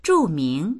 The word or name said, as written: zhu4ming2